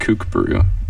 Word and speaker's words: Kokburu: So it's spelled Kokburu, but it's pronounced Kukburu.